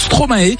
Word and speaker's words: Stromae: Je sais pas si vous aussi, ça vous énerve un petit peu, mais à chaque fois que j'entends une émission en France, ils prononcent Stromae, alors que c'est le verlan de Maestro, logiquement. J'ai jamais compris pourquoi. Ça fait quand même quelques années qu'il est là pourtant, hein, Stromae. Ça me paraît plus logique de dire Stromae, puis ça se dit comme ça aussi.